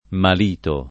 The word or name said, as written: Malito